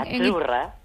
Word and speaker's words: azzurra: Azurra. – Azurra. Mira, ¿ves? – Azurra. Es que... Sí, como porque pizza, la Z no es la Z como es decimos en catalán tan sonora, no.